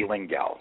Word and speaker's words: Lengyel: It's Eddie Lengyel.